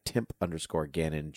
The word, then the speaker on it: Gannon: You can find Frank at Frank Ramblings on Twitter, and you can find myself at Timp_Gannon, G-A-N-N-O-N. Yes, I know my last name is the bad guy from Zelda. It is not spelled the same. I am aware.